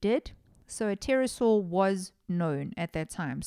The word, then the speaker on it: pterosaur: The men's description of the bird matched that of a pterosaur. Guys, that word always puts me off. You know, pterodactyl, pterosaur. Why is there a silent P in front of all of the flying reptilian things?